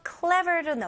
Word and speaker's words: clever: 여기서 cle 그다음에 v 발음으로 ver. ver 여기서는 v 발음 ver 이렇게 윗니 밑에 입술. ver 그다음에 r로 마무리하셔야 돼요. clever.